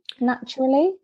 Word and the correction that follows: Naturli: Yeah, I struggle to pronounce that as well. But yeah, it's Naturli.